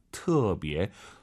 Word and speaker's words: 特: 무지무지, 特别 발음하실 때 터 아니고 트로 시작해야 돼요. 特别.